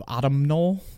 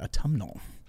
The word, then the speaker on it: autumnal: Yeah, I would have mispronounced it had I not heard it recently from you of autumnal. So I like that, autumnal.